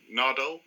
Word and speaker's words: Nardole: So Nardole, is that how you pronounce his name? – Yeah, Matt Lucas.